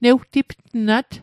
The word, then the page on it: newtiptnat: Mi'gmaq